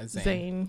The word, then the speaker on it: Zayn: Yeah, Zayn.